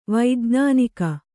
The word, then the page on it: ವೈಜ್ಞಾನಿಕ: vaijñānika